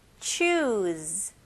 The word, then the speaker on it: choose: Z이지만 발음은 Z 발음이 나와야 됩니다. 오케이? 같이 한번 해볼게요. Choose.